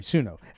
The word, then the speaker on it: Suno: Oh, for anybody who's just tuning into what we're talking about is, a website called, Suna.ai. Did I say it right? Suna? I just closed it. There we go. Oh, sorry. Suno. Suno.ai.